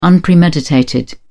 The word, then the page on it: unpremeditated: British English